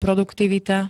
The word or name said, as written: produktivita